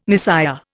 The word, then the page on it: Messiah: Indian